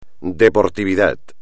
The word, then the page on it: deportividad: espagnol